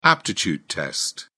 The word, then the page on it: aptitude test: British English